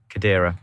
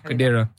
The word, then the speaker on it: Kadira: Sorry, Kadira. – Kadira. – Kadira. Sorry, my pronunciation was really bad there.